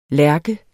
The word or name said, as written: lærke